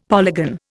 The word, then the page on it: polygon: Indian